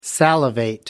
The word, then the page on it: salivate: American English